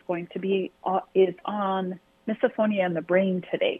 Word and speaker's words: misophonia: We're getting through October here and talking about misophonia, which I'd never heard of until the start of the month.